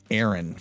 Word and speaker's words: ARIN: We're going to be saying Aaron from now on, and it's spelled A-R-I-N.